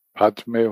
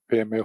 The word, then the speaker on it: Padme: Now I propose we sing the mantra of Avalokiteshvara, the Om Mani Padme Hum if we pronounce in Sanskrit, or Peme Hum if we pronounce in Nepali, Tibetan style.